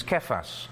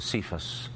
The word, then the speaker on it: Kephas: And we also know his Aramaic version of Petros, which is Kephas. Often mispronounced as Cephas.